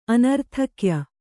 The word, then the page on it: ಆನರ್ಥಕ್ಯ: ānarthakya